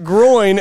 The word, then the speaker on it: groin: At first, I thought you actually said growing, and then I realized that you meant groin, and you just pronounced it growing. – It's G-R-O-I-N. – Groin.